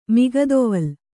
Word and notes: ಮುಕ್ತಾವಲಿ: muktāvali

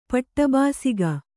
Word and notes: ಪಟ್ಟಬಾಸಿಗ: paṭṭa bāsiga

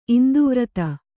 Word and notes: ಇಂದ್ವರ್ಧ: indvardha

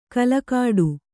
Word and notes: ಕಲಕಾಡು: Kannada; kalakāḍu

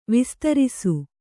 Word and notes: ವಿಸ್ತಾರಿಸು: vistārisu

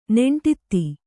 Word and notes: ನೆಂಟಿತ್ತಿ: Kannada

